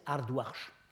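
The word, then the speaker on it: Ardoxsho: qu'on dit généralement Ardoksho, parce que c'est comme ça que ça s'écrit en bactrien. En fait, ça se prononçait Ardwarsh.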